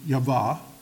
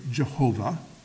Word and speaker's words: Yahweh: If you have capital G, capital O, capital D, all caps, it is referring to the unique name of God, which in Hebrew, the best they can figure it would be Yahweh. Comes Yahweh, can also be pronounced in our colloquial way, Jehovah.